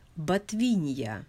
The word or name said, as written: Botvinia